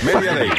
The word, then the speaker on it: Media Day: ¿Cómo se dice ese tú que se llama el día My Day ese? – Media Day. – El día My Day ese. – Media Day. – El Media Day.